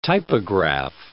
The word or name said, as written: typograph